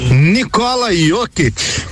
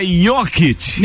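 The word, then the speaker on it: Jokić: Ah, escreve com I, Tio por que com J então? – Jokic. Pra quê? Não, é a pronúncia Tô lá, né? da onde ele vem, cara.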